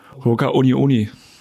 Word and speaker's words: Hoka One One: Ich bin jetzt mal auf einem Account und da habe ich hier, wie spricht man die eigentlich aus? Ho Hoka? Hoka? Hoka Uni Uni. Mhm. – Wie? Hoka? Uni Uni müsste, äh, ist richtig.